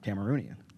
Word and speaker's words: Cameroonian: And then somebody told me, like, nah, one of them's, I thought, Cameroonian.